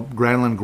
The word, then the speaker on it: Granlund: So Granlund is actually the real pronunciation.